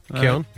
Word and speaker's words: Keoghan: Keown? – Yeah.